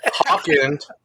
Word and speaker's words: Haken: I keep mispronouncing it. – All right, sorry, Haken.